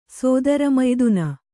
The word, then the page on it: ಸೋದರಮಯ್ದುನ: kannada